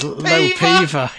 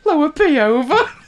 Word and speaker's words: Lower Peover: It's Low Peaver, yeah. – Find it on a map and share the link. They'll think we're joking. Lower P over. That is actually how it's spelled.